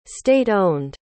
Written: state-owned

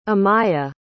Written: amiah

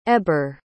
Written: heber

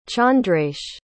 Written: chandresh

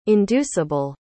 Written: induceable

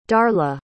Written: darla

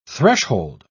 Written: threshold

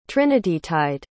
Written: trinitytide

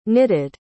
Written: knitted

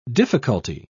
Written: difficulty

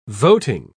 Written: voting

